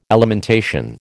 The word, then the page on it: alimentation: American English